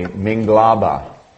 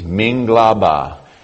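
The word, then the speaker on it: Minglaba (မင်္ဂလာပါ): Mike and I just got back from Southeast Asia, so I will say Minglaba. That's hello in Burmese, Minglaba. It's no accent on the end, Minglaba.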